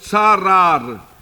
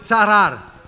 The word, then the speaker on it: tsarar: Das hebräische Wort ist tsarar. – Le mot hébreu c'est tsarar. – In unserer Sprache T-S-A-R-A-R. Mit der Betonung auf dem ersten A. – Écrit T-S-A avec accent R-A-R.